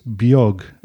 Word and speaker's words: beag: The Irish for small is beag, and that's spelled B-E-A-G, and often winds its way into the English language as B-E-G.